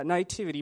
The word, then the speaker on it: naivety: And her weakness is sort of naivety. Did I say that right? Yeah, naivety, meaning